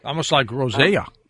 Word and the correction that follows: rosacea: right? Is that how it's pronounced, Doc? Rosea? – Well, rosacea. – Rosacea, is that how it's pronounced? – Yeah, it could be rosacea.